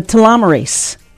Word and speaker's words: telomerase: The telomerase revolution. – I think that's a good pronunciation.